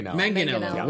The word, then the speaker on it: Manganiello: Hard G. – Just the... – Manganiello.